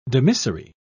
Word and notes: dimissory: British English

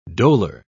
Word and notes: dolour: British English

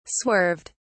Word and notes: swerved: British English

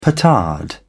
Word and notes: petard: British English

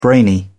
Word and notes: brainy: British English

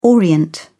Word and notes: orient: British English

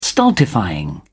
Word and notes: stultifying: British English

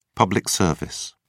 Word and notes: public service: British English

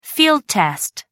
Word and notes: field-test: American English

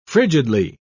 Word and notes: frigidly: British English

